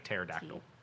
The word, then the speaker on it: pterodactyl: K-R-O. – C-H-R. – Yeah. – O-M-A. – No, it begins with a P. It's like pterodactyl. – Yeah. – You know why you can't hear a pterodactyl P or go to the bathroom? I killed it. Because the P is silent.